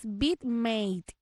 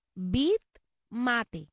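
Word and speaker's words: VidMate: La app que usted puede navegar es BitMate. Se escribe V I latina, D de dedo, M de mamá, A T E. BitMate. Es decir, BitMate en español.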